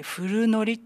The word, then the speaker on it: 古糊: 10年間、まあ、いわゆるそれ古い糊と書いて古糊って言うんですけど、